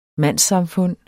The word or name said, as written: mandssamfund